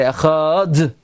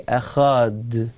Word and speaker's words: Echad: By the way, you're not even supposed to say Krishma that way, because if you say Echad, you're making the D, and that's not the correct way to say it. Actually, Echad, you just say the Dalet as a Shva Nach.